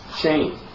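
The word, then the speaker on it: shame (שֵׁם): And the word for good name is pronounced shame. Interesting. The Hebrew word is shem or shame.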